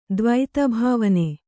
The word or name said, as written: ದ್ವೈತಭಾವನೆ